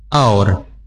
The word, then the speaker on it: hour: Jam, so when it's related to time, so actually this should be pronounced with hour. – Hour. – Hour. – Hour. – The H is silent. Not yes. Hour.